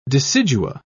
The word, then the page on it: decidua: British English